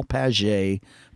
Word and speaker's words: Padgett: And my last name, for any of you all that, a lot of people pronounce it Paget. So the last name is P-A-D-G-E-T-T, Paul Paget Photography.